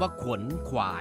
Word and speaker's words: ขวนขวาย: พยางค์แรกของคำว่าขวนขวายต้องออกเสียงขอไข่ควบกับวอแหวนสระโอนอหนูสะกดไม่ใช่ขอไข่สระอัวแล้วนอหนูสะกด